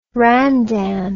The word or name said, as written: randan